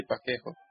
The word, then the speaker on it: Pacheco: P-A-C-H. You speak Portuguese. P-A-C-H-E-C-O. Any pronunciation on that last name? – Sí. Javier Cedinho de Paqueso.